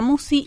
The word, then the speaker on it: Musci: El canal lleva mi nombre, Marisa Musi. – ¿Cómo se escribe Musi? – M-U-S-C-I.